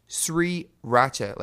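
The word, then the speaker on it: 3RACHA: And they're known as Sri Racha, like three, the number three, Racha. And in Korean, you pronounce three as Sri.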